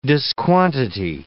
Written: disquantity

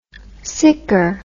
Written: siccar

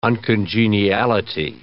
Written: uncongeniality

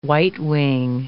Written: whitewing

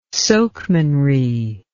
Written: sokemanry

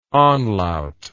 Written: anlaut

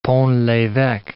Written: Pont l'eveque

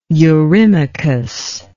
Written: eurymachus

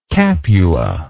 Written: capuan

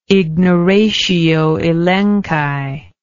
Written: ignoratio elenchi

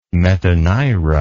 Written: metanira

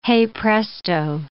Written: hey presto

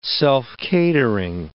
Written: self-catering

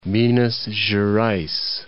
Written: Minas Gerais

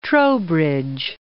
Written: Trowbridge

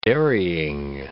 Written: dairying

